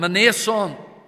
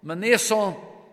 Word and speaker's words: Mnason: You see, when you look at the name that's given there in verse 16, it starts with two consonants, and we're not used with that. But the way to perhaps help with pronunciation is to insert a vowel there between the M and the N and to call him Mnason.